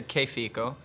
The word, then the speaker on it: Che Fico: So you say Che Fico. – Che Fico. – Right. – Yeah. – You know, I mean, I've heard every iteration of it. Che Fico. Che Fico.